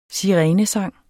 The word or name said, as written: sirenesang